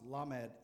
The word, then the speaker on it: Lamed: The 12th character in the Hebrew alphabet is pronounced Lamed, L-A-M-E-D, Lamed, which literally means perfection or Solomon-like wisdom.